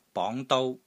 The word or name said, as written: BONG2 DOU1